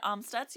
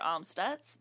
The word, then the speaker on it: Amstutz: I'm Sandra Amstutz. You can find all my social media at Sandra Amstutz.